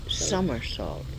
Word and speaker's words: somersault: Somersault. It's summer, I believe. S-O-M-E-R, and it's somersault.